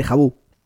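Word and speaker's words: Jabu: Por cierto, ahora que dije de Vu, es de Jabú. – De Jabú, claro, sí, sí, sí De unicornio. Sí, lo dije, lo pronuncié mal, discúlpenme.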